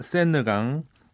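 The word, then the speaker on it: Seine: 1번 센느강, 2번 테임즈강, 3번 소양강, 4번 만수무강. – 네. 느강은 어떻게 발음해요? – 센느. 아, 그냥 센느라고 네, 해요.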